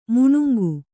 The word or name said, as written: ಮಾನಂಗಿ